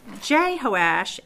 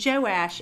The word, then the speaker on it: Jehoash: Then, and I've left out some unnecessary details, then Jehoash, and Joash is the other way that's pronounced, and I'm going to go with that because it has fewer syllables.